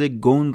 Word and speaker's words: Ghosn: تلفظ گون رو به جای غسل جا انداخت